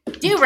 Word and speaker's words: Durant: You gotta say it like Durant. – Durant. That's exactly right. It's Durant. – Like Durant. That's right. Yeah. – They'll know you're not from there if you say it wrong.